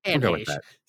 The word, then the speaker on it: Anne Heche: How do you say her name? Anne Heche? – Anne Heche.